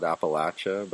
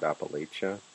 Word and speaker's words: Appalachia: And, you know, there's even different pronunciations for it depending on how far south or north you are, right? Down here we call it Appalachia, but up north they call it Appalachia.